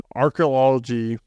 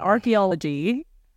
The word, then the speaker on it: archaeology: reflecting the changing techniques and theories in archaeology. – You keep adding a random syllable to that. Archaeology.